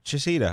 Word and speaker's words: Chaseedaw: Chaseedaw, I said that right, correctly, finally? – Yes, you did.